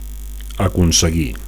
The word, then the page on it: aconseguir: Catalan